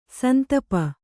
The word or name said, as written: ಸಂತಾಪ